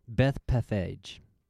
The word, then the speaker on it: Bethphage: Bethphage.